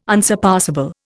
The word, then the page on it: unsurpassable: Indian